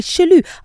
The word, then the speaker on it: chelou: So, C-H-E-L-O-U. – Chelou. And it's not about doing jealous. Jelou. – So it's Verlan. So L-O-U-C-H-E. Louche. – Louche. L-O-U-C-H-E.